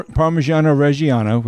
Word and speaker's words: Parmigiano Reggiano: Accepts par- Parmigiano Reggiano, which I said that correctly, cheese as collateral for small business loans.